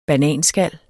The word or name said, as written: bananskal